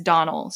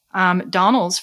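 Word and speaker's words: Domhnall: He himself pronounced it as Donald, so I'm going to go with his pronunciation. But his co-stars were all calling him Donal, so I don't know. But if I'm the guy himself said Donald, so Donald Gleason, we're going with it.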